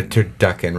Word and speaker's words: turducken: Wait, is it turducken or turduncan? – Turducken. – No, N. – I've been saying it wrong. – No middle N.